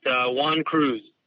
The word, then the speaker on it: Juan Cruz: How do you say your name? – It's Juan Cruz.